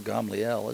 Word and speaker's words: Gamaliel: But a Pharisee in the council called Gamaliel, or Gamaliel is the way that it's pronounced in Hebrew,